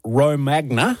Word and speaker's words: Romagna: We tend to put the emphasis on the wrong syllable here. – Or as we would say here, Romagna.